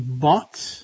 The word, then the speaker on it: bought: Este UGH, en general, tiene dos pronunciaciones. Y vais a ver qué fácil es esto. O tiene un sonido F, o no suena a nada. Entonces, aquí sería directamente B o T. Ejemplo de esto, bought. Otro ejemplo, el pasado de catch, que es coger, es caught. C-A-T, algo así parecido.